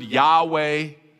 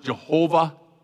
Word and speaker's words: Yahweh: Lord in all capital letters in the Old Testament, that's the Hebrew word Yahweh. Sometimes it's pronounced Jehovah.